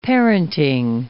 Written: parenting

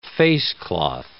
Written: facecloth

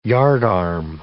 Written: yardarm

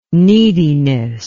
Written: neediness

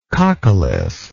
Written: coccolith

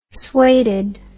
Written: sueded

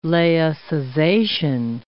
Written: laicization

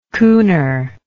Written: cooner

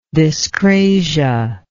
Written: dyscrasia

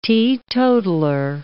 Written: teetotaler

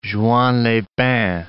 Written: Juan-les-Pins

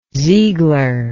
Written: Ziegler

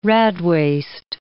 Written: radwaste

